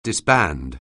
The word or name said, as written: disband